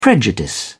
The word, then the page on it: prejudice: British English